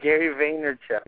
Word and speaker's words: Vaynerchuk: Gary Vaynerchuk.